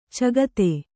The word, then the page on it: ಚಗತೆ: cagate